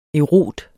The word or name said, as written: EROTERNE